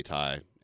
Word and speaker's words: Ty: Ty is it rhymes with a lot. And, you rarely call me Ty, Erin.